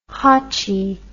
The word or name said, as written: hotchee